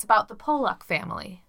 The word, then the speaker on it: Pollock: I feel like I would say Pollock. – P-O-L-L-O-C-K. – That's how I would pronounce that word. – Pollock. Okay, great. – I'm no pronunciation, but I would say Pollock.